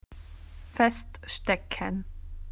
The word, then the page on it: felt tip pen: Australian English